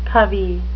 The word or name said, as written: covey